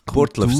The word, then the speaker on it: Burgdorf: In die Burtlöf, sagt man. Burtlöf.